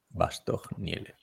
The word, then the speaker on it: Bastogne: Y pasamos a, a, la Bastoña Lieja, porque las chicas salen de Bastoña y no salen de, de Bastogne. ¿Cómo se pronuncia? ¿Bastogne? Bastogne.